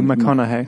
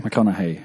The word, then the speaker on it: McConaughey: Matthew McConaughey. På massa rätt, jag vet inte vad man uttalar. – Men Matthew McConaughey. – McConaughey, ja.